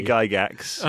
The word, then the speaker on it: Gygax: We've got a lot of jokes built around people whose names have a hard G in them. – That's very good. We love that. – A surprising hard G. – Gary Gygax.